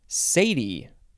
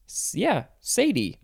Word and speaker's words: cedi: Next we have Sadie. Sadie. But it is spelled C-E-D-I. That's not how I would want to pronounce it, but that's what the book tells me.